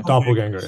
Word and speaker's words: doppelganger: Like dopalanger or something like that? – Yeah. Doppelganger, yeah.